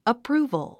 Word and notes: approval: əprúːvəl; アップルゥボゥ

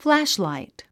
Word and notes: flashlight: flǽʃlàit; フラッシュライト

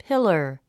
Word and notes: pillar: pílər; ピラァ

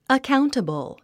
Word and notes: accountable: əkáuntəbl; アカァウンタボゥ

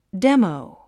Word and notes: demo: démou; デモウ

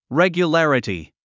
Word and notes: regularity: règjulǽrəti; レギュララティ

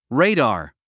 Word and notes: radar: réidɑː r; レイダー

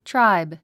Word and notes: tribe: tráib; トゥライブ

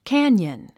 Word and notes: canyon: kǽnjən; キャニオン

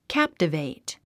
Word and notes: captivate: kǽptəvèit; キャプタベェイト

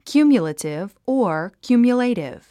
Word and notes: cumulative: kjúːmjulətiv; キューミュラティブ

